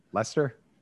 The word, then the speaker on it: Leicester: No, it's like Leicester is what it looks like. – Oh, yes. – Yeah. But it's pronounced like Lester.